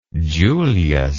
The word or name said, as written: julius